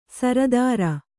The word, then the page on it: ಸಾರ್ತರ್: sārtar